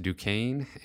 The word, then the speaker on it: Duquesne: I'm told the university is pronounced Duquesne,